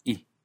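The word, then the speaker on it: hi: La partícula en y la partícula i, que se escribe h-i, i.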